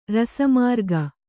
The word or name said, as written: ರಸಮಾರ್ಗ